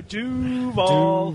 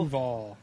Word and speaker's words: Duval: Yeah, we need the long U. Duval. – Duval.